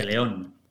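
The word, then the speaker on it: Pellaeon: Acordaros de Pelaleón. Ah, perdón, no lo pronunciado bien. Eh, Peleón. peleón. Como el vino.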